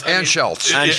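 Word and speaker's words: Anschutz: And Auschwitz, or however, I'm sorry, however you pronounce his name. – Auschwitz, yeah. – Auschwitz might not be the, yeah. – No, it's close. – Anschultz. – Anschultz.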